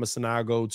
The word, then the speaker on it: Sanogo: He said, Sanogo, bro, you don't want to know. You got to pronounce that a little bit. You got to be quicker on that, bro.